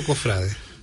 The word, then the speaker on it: cofrade: ¿Cofrade o cofrade? – Sí, lo vi de las dos maneras en los últimos días. Y la palabra es cofrade, sin tilde, con pronunciación grave o llana.